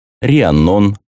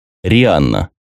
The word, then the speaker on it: Рианнон: Как и все старые имена, вышедшие из королевства, имеет разное произношение в разных областях страны. Рианнон, Риннон, Риеннон, Рианна и так далее. . 1895 год. Год начала Великой войны. Рианна. Так звучит это имя в местности, где родился и живет Итан.